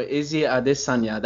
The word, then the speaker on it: Adesanya: Izzy Adesanya. That's how you say his name properly, by the way.